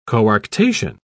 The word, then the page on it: coarctation: British English